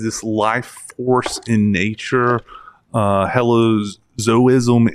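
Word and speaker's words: hylozoism: So animism, helozoism, did I pronounce that correctly? – Correct.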